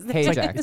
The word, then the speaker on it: Hajek: Hajek. – It's like paycheck.